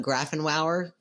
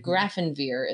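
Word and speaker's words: Grafenwöhr: It's like, spelled phonetically. Grafenwier. – Definitely not Grafenwauer, because that would be quite the name. – There's no V in the German spelling, but Grafenwier is how you would say it.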